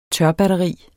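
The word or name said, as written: TØRBATTERI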